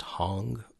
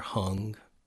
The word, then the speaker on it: hang: It's spelled H-A-N-G, but pronounced hong or hung.